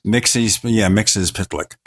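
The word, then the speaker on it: Mxyzptlk: But Mitzelplex. Mitzelplex. – Mixes Pitlick. That's, I think, one pronunciation of it that I heard.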